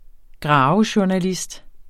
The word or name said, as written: graverjournalist